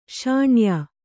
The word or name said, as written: ಶಾಣೆಯ